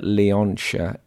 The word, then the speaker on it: Leontia: Anyway, Leontia Flynn wrote a collection of poems I'm fairly confident, by the way, that Leontia is how you say her first name. It's L E O N T I A.